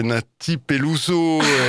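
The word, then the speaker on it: Nathy Peluso: Et non, je le prononce mal parce que il m'a effacé la moitié du mot, c'est Nati Peluso, et non pas Nati Pi tout court, avec Menina.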